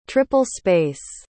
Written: triple-space

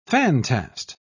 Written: fantast

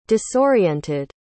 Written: disoriented

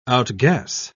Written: outguess

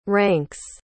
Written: ranks

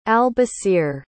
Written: al-basir

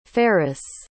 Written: farris

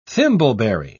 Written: thimbleberry

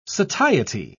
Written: satiety

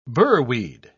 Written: burweed

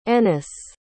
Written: ennis

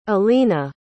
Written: alina

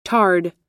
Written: tarred

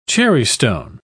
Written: cherrystone

